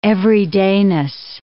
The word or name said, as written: everydayness